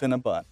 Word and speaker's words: Cinnabon: But it's spelled Bon, but it's Cinnabon.